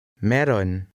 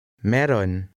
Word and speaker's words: Meron: Meron. Meron. – Notice that the two vowels are not pronounced separately in this word, and the AY combination is pronounced E.